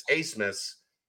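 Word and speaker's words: Abmas: I was watching their Big East opener, or their Big 12 opener, against Texas Tech, and the announcer said it's Acemus. Apparently in Germany, that spelling triggers a CC pronunciation instead of a B.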